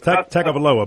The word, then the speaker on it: Tagovailoa: It's Tagovailoa, but it's Tua Tagovailoa.